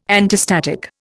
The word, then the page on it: antistatic: Indian